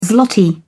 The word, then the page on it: zloty: British English